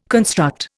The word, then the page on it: construct: Indian